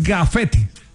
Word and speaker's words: gafete: No, porque no es grafetes. – Ah, ok. – Es gafete. – Válido. No lo entendía, no lo pronuncié bien, tiene toda la razón.